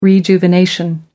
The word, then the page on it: rejuvenation: American English